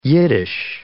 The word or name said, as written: Yiddish